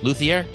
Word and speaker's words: luthier: And we also talked about your career as a luthier. Luthier, did I say it right? Yeah, Luthier.